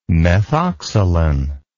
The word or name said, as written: methoxsalen